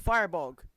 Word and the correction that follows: Firbolg: I believe it's pronounced Firbolg. – That is correct.